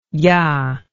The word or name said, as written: ja